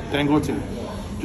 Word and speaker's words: Dangote: Dangote.